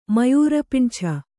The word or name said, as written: ಮಯೂರಪಿಂಛ